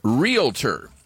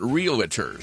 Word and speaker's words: realtor: It's realtor. There's only two syllables, not realtor. Sorry. Realtor.